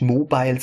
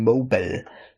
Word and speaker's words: Mobal: Mobile übrigens M-O-B-A-L, nicht Mobile, sondern ich habe das schon richtig ausgesprochen, Mobile.